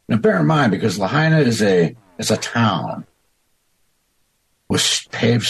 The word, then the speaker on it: Lahaina: As was his deputy chief, Hank, and both of them now are leading this investigation, or are in, how do you pronounce that, Lahaina? – Lahaina.